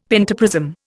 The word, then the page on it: pentaprism: Indian